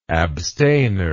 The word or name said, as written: abstainer